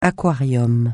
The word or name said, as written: aquarium